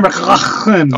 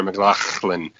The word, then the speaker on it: McLachlan: No, you said that wrong. It's Sarah McLachlan. – Oh, excuse me, Sarah McLachlan. – Yeah, so you gotta sound like you're hawking up a loogie in the middle.